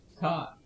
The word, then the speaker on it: Thai: And he learned how to pronounce the word Thai. – Tie.